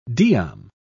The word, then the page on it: diam: British English